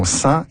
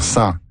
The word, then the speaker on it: sens: La prononciation « sans » est vieillie. C'était la prononciation en usage dans tous les pays francophones jusqu'au 19e siècle. La prononciation « ça n'a pas de bon sens » est peut-être ancienne, mais elle n'est pas fautive.